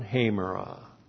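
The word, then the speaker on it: Hamera (ἡμέρα): It modifies the noun hamera. H, long E, M, E, R, A. Hamera.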